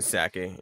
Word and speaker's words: Psaki: Psaki.